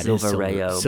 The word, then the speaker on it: Silverio: Silvio? – It's not Silvio. It's not that. – Oh, I thought it was. – There's another. There's another syllable in there. – Oh yeah, Silverio.